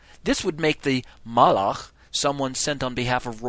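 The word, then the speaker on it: malach (מלאך): The Hebrew word for king is melech, okay? And this has a similar sound to our word for messenger, which is malach. You hear the two? Melech, which is spelled M-E-L-E-K-H, and Malach, which is M-A-L-A-K-H.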